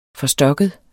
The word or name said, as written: forstokket